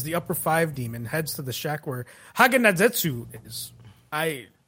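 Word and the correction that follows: Haganezuka: It's so Haganazuka. Haganazuka.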